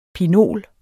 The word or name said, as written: pinol